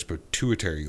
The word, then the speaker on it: pituitary: A tumor near his pituitary gland.